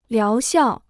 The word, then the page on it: 疗效: liáo xiào